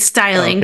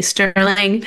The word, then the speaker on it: Steiling: I mean, actually, that's a pretty common pronunciation of my last name. It's Styling, okay. But a lot of people accidentally say Sterling, a little more familiar.